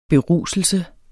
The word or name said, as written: beruselse